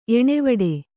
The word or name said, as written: ಎಣೆವಡೆ